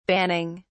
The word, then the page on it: banning: British English